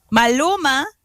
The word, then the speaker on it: Maluma: Malo- Maluma, Maloma. I can't even pronounce them. Like I'm so lost. – Perla, you're showing your age. Maluma.